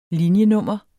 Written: linjenummer

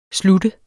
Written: sluttet

